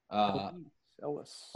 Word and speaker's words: Elwes: Elwes? – Elwes.